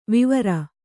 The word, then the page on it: ವಾವುರ: vāvura